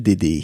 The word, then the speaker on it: Dayde: Par exemple, en 1971, Sophie Macnot chantait Mon bel HLM et en 1973, Joël Dédé, j'ai envie de le prononcer Dédé, ça s'écrit D-A-Y-D-E, Joël Dédé chantait HLM Blues, donc c'est un chanteur français et c'est lui que j'ai choisi.